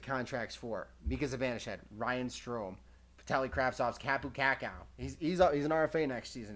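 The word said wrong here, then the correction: Kaapo Kakko: How do you say Kapu Kakao? Kapo Kako.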